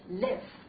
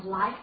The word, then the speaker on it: live: 注意，其实这个词呢发音上跟这个离开呢有肯定有很大区别，一个是 leave，一个是 live 啊，短音对不对？live。啊，很短促。